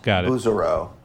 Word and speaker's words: Bouzereau: It's Laurent. It's Laurent Bouzereau. That's the American way of saying Laurent Bouzereau.